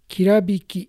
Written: Kirabiki

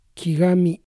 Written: Kigami

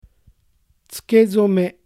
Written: Tsukezome

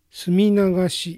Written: Suminagashi

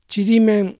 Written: Chirimen

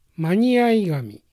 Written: Maniaigami

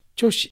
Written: Choshi

